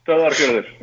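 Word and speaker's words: Stöðvarfjörður: It's Stodvarfjordur. Is that about right? – Not bad. Yeah, not bad. That's pretty close to it. Stodvarfjordur. Yeah. Yeah, you're right.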